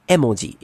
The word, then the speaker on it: emoji: Because so emoji, everyone calls it emoji, right? But really in Japanese it's emoji. It's e as in picture, and moji as in character. It's a picture character, literally.